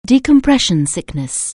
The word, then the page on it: decompression sickness: anglais